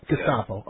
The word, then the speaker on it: Gestapo: Geheimstapolizei. Did I say it wrong? I'm sure I did. – Yeah, Gestapo. – Gestapo, yeah.